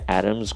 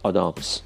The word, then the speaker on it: Adams: تلفظش کاملا نادرست دیگه به جای ارمز گفتن آدامز